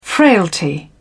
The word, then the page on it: frailty: British English